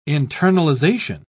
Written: internalization

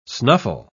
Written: snuffle

